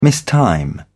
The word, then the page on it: mistime: British English